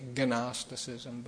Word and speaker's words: Gnosticism: Sounds like it starts with an N, but it starts with a G. You'd think you'd say Gnosticism, but that's not how it's pronounced. The name comes from a Greek word gnosis, which means knowledge. G-N-O-S-I-S.